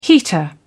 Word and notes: heater: British English

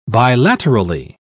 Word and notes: bilaterally: British English

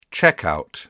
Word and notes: checkout: British English

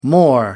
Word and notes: more: American English

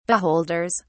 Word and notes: beholders: British English

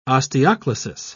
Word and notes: osteoclasis: British English

